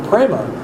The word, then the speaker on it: prema: But this word prema, it's P-R-E-M-A, pronounced prem.